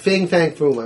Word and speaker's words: Fing Fang Foom: Fing Fang Foom.